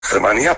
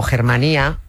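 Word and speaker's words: Germania: Germanía para sustentar este poder. – Lo que pronunciamos los castellanos a lo bruto como Germanía es en realidad Germanía, en valencià. Germà es hermano, Germanía, hermandad.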